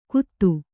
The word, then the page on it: ಕುತು: kutu